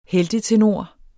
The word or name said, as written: heltetenor